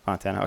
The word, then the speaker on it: Fontana: Is it pronounced Fontana? Am I saying that right? – Yeah. – Fontana. Okay. Mm-hmm. I don't know. I thought I was like saying it like Fanta. – No, that's good. Fontana.